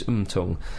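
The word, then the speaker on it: Motaung: And one of the Pirates' most famous players, who's called Kaiser Mtung, I think is pronounced Mtung. – Mtung. It's spelt Mo Twang, but I've been told by South African friends that it's Mtung.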